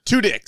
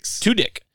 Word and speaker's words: Tudyk: Tudyk. – Tudyk.